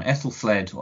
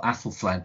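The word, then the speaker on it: Æthelflæd: You've just cited a very good one, Ethelfled or Athelflaed, depending on how you say it.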